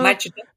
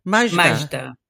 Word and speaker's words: Majda: May I pronounce Magda, your name? – Yes, but it's also in Arabic. It's also Majda. – Majda. Yeah.